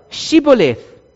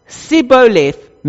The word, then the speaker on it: Shibboleth: The Gileadites were smart though, and they knew that the Ephraimites couldn't pronounce a specific word. And that word was Shibboleth, meaning stream. When they instead said, the Ephraimites, Sibboleth, meaning an ear of grain. And if they mispronounced this word, they were killed on the spot.